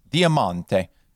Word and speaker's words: diamante: In Italian, it's pronounced diamante. The accent's over the mon instead of the te.